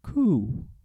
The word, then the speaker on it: coup: Oh, January 6th, it was a coup. It was an invasion on our country. – Coup. – A silent P. Coup.